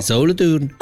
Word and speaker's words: Solothurn: si pronuncia Solothurn? – Eh sì, in tedesco. – Davvero? – Sì, Solothurn.